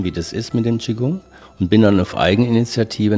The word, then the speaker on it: Qigong: Sagt ja auch Qigong, nicht Qigong. Man sagt Qigong. Dieses O von G-O-N-G wird ausgesprochen wie ein U. Qigong.